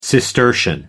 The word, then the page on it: cistercian: American English